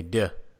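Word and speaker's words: deux: How do you say the French thing? Duh? – That sounded pretty good to me. Duh, I'm thinking that movie Hot Shots, and then Hot Shots part duh.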